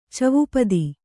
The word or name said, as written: ಚವುಪದಿ